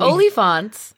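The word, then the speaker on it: oliphaunt: The servants also have elephants and Sam pronounced elephants very weirdly. – Olyphants. They're called olyphants. – Okay. – They're not elephants.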